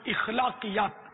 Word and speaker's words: اخلاقیات: اس سوال میں دو تلفظ, ایک تو وہ اخلاقیات, الف پر زبر ہے اخلاقیات, اخلاقیات نہیں, عوام اخلاق بولتے ہیں, اصل تلفظ ہے اخلاق, اور اساتذہ خواص بھی بولتے ہیں, مشہور ہے اساتذہ.